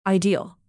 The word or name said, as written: ideal